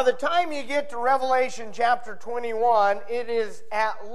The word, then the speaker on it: Revelation: your Bibles with me to the book of Revelation, chapter 21. You know, sometimes people mispronounce this book. It's not Revelations. There's only one.